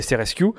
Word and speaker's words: SRSQ: c'est la lettre S, la lettre R, la lettre S, la lettre Q, qui se prononce du coup SRSQ.